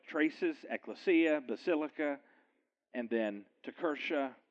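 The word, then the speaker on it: Kirche: So the kalika became this next word, which is Tekirche. I know it doesn't look like Tekirche, but that's the way it's pronounced, Tekirche.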